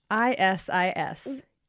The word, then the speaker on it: phthisis: Ancient Greek physicians described the pulmonary form of tuberculosis and called it phthisis, which is spelled horribly. All right, you ready? – I'm ready. – Phthisis. Yeah, that's a PH and a TH at the beginning.